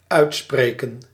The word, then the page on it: uitspreken: /ˈœy̯tˌspreːkə(n)/